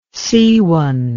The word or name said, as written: seawan